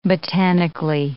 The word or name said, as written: botanically